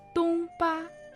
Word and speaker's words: Дунба: Тунба, тун и ба, оба они в первом тоне.